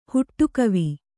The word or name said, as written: ಹುಟ್ಟುಕವಿ